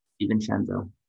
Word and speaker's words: DiVincenzo: DiVincenzo. I can't even pronounce it, dude. – DiVincenzo.